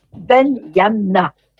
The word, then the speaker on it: Benyamna: Son vrai nom, c'est Mohamed Benyamna. Benyamna, voilà, je ne l'écorche pas.